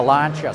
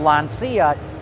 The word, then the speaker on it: Lancia: I'm standing in the Lancia stand right now. Now, I know it looks like it says Lancia, but it's actually pronounced Lancia.